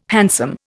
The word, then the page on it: handsome: Indian